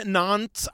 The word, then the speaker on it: Nantes: So we learn about this town, Nantes, I think is how they say it, N-A-N-T-E-S.